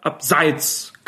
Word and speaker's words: Abseits: Es heißt natürlich abseits mit Betonung auf der ersten Silbe, auch wenn der langjährige DFB-Schiedsrichterlehrwart Eugen Striegel viele werden ihn auch noch kennen von seinen Auftritten im aktuellen Sportstudio, das immer anders betont hat.